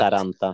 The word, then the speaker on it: Hietaranta: Sara Beth Gray slår Påvelunds etta, finskan Laura Hintaranta. Två raka set. – Hietaranta. – Hietaranta, oklart uttal.